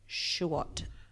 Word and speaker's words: Chuot: Okay, A-Y-O-R, Aor, McKerr, M-A-K-U-R. – Oh, yeah. – Schwott, okay. C-H-U-O-T.